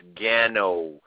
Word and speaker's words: GANO: And you're not, but that's okay. It's all right. It's GANO, but some people have GANO. – Some people have a different tongue, and I've come to understand that.